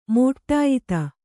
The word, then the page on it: ಮಡಿಯೊತ್ತು: Kannada